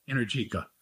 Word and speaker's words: Energica: Energica.